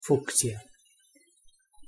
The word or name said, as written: fucsia